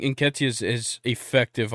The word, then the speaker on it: Nketiah: I've always said Nikita. – I think it's Nketiah. – Nketiah? Yeah, okay, that makes more sense.